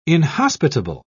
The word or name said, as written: inhospitable